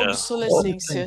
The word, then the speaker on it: obsolescência: A pronúncia é obsolentismo ou obsole... – Obsole... – obsolescência? Obsolescência. – Obsolescência. – O que que eu falei? – Você falou certo.